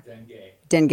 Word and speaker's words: Dengue: Dengue fever? – Dengue.